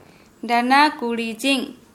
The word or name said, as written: dana kuridʒiŋ